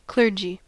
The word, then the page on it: clergy: American English